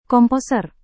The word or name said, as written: komposer